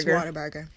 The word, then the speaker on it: Whataburger: How do you pronounce, I'll say it properly, Whataburger? – It is Whataburger. – It is Whataburger.